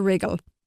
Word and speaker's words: wriggle: Aha, so that's why it's so easy for them to wriggle. You are quite the expert on wriggling, Caelan. So it's spelt W-R-I-G-G-L-E, as we said, but that has a silent W.